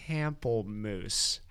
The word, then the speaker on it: Pamplemousse: So today I'm drinking LaCroix Pamplemousse. How in God's name do you pronounce that? – Nailed it. – Did I? Okay, good. – Pamplemousse.